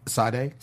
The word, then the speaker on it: Sade: Sade. Okay. – Yeah. Kind of like the singer. – Oh, okay. – Yeah, you just pronounce that Sade all the time.